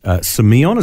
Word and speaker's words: semeion: Semeion, is that how you pronounce yep.